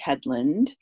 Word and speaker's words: Hedlund: So my website, my personal website, or author website, I should say, is Julie Hedlund. My last name is spelled H-E-D-L-U-N-D.